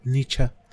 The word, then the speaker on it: Nietzsche: Nietzsche, Nietzsche, o bien pronunciado Nietzsche.